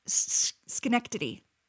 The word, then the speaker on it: Schenectady: Schenected. – Oh, Schenectady.